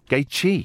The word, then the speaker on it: Gaethje: Let's get this pronunciation right. Gaethje? – Gaethje. Yeah, Gaethje.